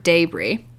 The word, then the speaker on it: debris: Roast beef style po' boys are often served with débris, spelled like debris, but pronounced débris, which is basically bits of meat that fell off during cooking and are rendered into like a near gravy consistency.